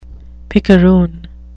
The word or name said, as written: picaroon